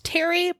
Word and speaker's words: Tere: And when she was 12, she changed her name to what I believe is pronounced Terry, but it's spelled Tare, T-E-R-E.